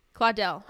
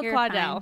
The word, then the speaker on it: Claudel: Claudel. – Huh, sorry. Yeah, Camille Claudel.